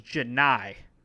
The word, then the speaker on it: Johni: Been calling him Johnny. His name is Johni Broome.